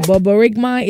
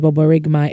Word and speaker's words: borborygmi: Boborygmai is the medical term for the stomach growling or rumbling, which is usually a normal sign of digestion rather than a symptom of a disease. That is Boborygmai. It is spelled B-O-R-B-O-R-Y-G-M-I. Boborygmai. Bobo no. G she inside Boborygmai. B-O-R-B-O-R-Y-G-M-I.